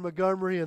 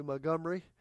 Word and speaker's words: Montgomery: Welcome to Montgomery. Actually, you don't pronounce the T. Some do, but some don't.